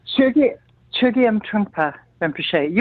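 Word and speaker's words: Chögyam Trungpa Rinpoche: She has been a student of, she's going to probably correct me, but I'm pronouncing this as Chogyam Trungpa Rinpoche since the age of 25. Did I get that right, or how do we pronounce that? – Chogyam Trungpa Rinpoche. You were close.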